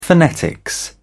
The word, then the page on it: phonetics: British English